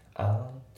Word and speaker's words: aren't: で、まあ、発音なんだけど、まず aren't の t がね抜けますね。 aren't cheap ですね。 aren't cheap じゃなくて aren't cheap。